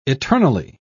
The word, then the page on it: eternally: British English